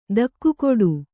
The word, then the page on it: ದಿಗ್ಗೇಡಿ: Kannada